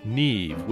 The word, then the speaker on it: Neve: Their name may seem odd, Neve, which is spelled like leave, but with an N.